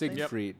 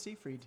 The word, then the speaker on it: Seyfried: Is that how you say it last Siegfried. – Name? – Yep. Siegfried. – Siegfried? No, there's no G in it. I think it's Seyfried. Seyfried.